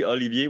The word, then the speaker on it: Olivier: Olivier, the Canadian gangster, Aubin, am I pronouncing that correctly? – Well, yeah, it's pretty good. Yeah, it's pretty good. Actually, Olivier, when you said Olivier, it's really, really good.